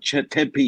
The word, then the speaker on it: Tempe: Those syllables like Tempe, not Chandler or, Scottsdale, but Tempe.